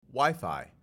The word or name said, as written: WiFi